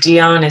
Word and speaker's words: Deon: And Deon is D-E-O-N.